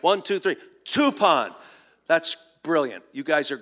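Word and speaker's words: tupon (τύπον): It rhymes with a word we're used to in our English language. The Greek word, for example, is tupon, T-U-P-O-N, English spelling. Tupon rhymes with coupon.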